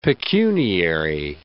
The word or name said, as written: pecuniary